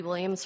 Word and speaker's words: Williams: My name is Becky Williams from Lewistown on behalf of American Prairie.